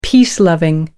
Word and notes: peace-loving: American English

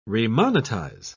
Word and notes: remonetize: British English

